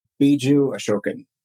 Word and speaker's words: Biju Ashokan: Biju Ashokan.